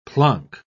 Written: plonk

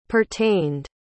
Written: pertained

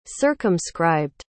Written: circumscribed

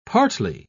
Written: partly

